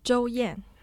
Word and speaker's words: Zhou Yan: Zhou Yan. – Zhou Yan. So it's first tone, fourth tone.